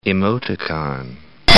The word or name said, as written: emoticon